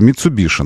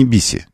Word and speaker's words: Mitsubishi: Что Mitsubishi надо произносить. Вот в нашей транскрипции это Mitsubishi называется. Не Биси. Нет, не Биси никакой.